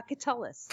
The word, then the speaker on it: Catullus: Uh, Catullus.